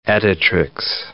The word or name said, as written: editrix